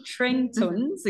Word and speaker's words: Tretorn: And on these rubber boots, so it's spelled T-R-E-T-O-R-N, Trentons, in case I'm not pronouncing it properly.